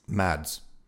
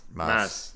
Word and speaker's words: Mads: You don't pronounce the D. – You don't? Mas. – Nope. I heard that on Twitter confirmed. – We from Bjorn? – With Bjorn. He's like, it's like, yeah, it's like Mas.